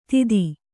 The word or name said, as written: ದಿತಿ